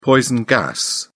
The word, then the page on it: poison gas: British English